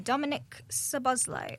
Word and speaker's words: Szoboszlai: Dominic Szoboszlai. – Oh, very well pronounced.